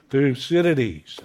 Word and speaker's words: Thucydides: So if nothing else today, you can tweet your friends, I met a great thinker, and I can even pronounce his name, Thucydides.